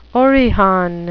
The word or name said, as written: orihon